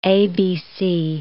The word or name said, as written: ABC